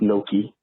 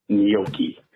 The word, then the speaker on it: gnocchi: It is not gnocchi. It's gnocchi.